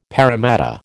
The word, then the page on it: paramatta: American English